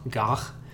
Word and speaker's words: Gough: Michael Goh, if I'm saying that right. Is that how you say his last name? – Gough.